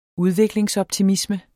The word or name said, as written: udviklingsoptimisme